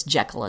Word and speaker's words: Jekyll: A lot of people call this Jekyll and Hyde, and you are certainly welcome to do you. That said, I'm going to go with Jekyll for a couple of good and maybe not so good reasons. The good reason is that Robert Louis Stevenson wanted it pronounced that way.